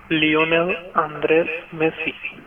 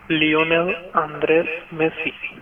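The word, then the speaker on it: Lionel Andrés Messi: Lionel Andres Messi。 – 听清楚了吗？我们再来一遍。 – Lionel Andres Messi。 – Lionel Andres Messi。通常呢，大家会叫他 Leo Messi。 Leo Messi。刚才那个发音是西班牙语的发音。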